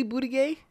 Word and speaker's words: Buttigieg: Is it really Buttigieg? – Yeah.